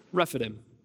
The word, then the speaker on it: Rephidim: Rephidim. That's how the guy on the Bible app pronounced it.